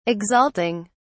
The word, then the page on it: exalting: British English